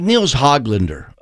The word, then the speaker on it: Nils Höglander: What I was doing, though, like Nils Höglander.